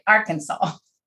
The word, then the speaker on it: Arkansas: And I said that right, Arkansas. – You did.